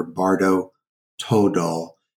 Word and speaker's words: Bardo Thodol: or Bardo Todol is another, is how they say it in Tibetan.